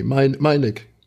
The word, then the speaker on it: Melnyk: Auch der Beef gerade zwischen dem ukrainischen Botschafter, ich habe immer Angst, den Namen falsch auszusprechen, Herr Andriy Melnyk, sage ich. – Nee, Melnyk. – Ach, so spricht man das aus, okay, sehr gut.